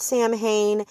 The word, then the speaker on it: Samhain: It is spelled S-A-M-H-A-I-N. It is not Samhain. It is Samhain.